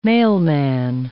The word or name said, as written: mailman